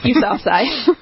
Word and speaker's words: Yousafzai: Yousafzai.